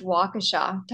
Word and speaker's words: Waukesha: It's actually pronounced Waukesha. But, you know, it was close. I like your pronunciation. – Waukesha? Who, like, pronounces a hard E in the middle of a word like that? – Wisconsinites.